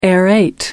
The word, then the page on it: aerate: American English